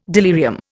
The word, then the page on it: delirium: Indian